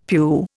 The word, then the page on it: pew: Indian